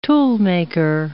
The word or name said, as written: toolmaker